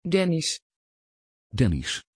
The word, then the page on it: dennys: Dutch